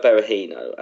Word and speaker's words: Berahino: Saido Berahino, as to correctly pronounce his name.